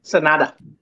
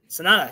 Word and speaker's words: Sanada: Is it Sanada? Is that you pronounce it? Sanada? – Sanada. – Sanada, yes. So Sanada versus Jungle Boy Jack Perry.